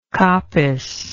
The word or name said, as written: coppicing